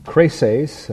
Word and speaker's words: Crescens: And he describes in 10, "For Demas hath forsaken me, having loved this present world, and is departed unto Thessalonica, Crasses," again that's the way his name really would be pronounced, "Crescens to Galatia, Titus to Dalmatia.